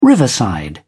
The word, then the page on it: riverside: British English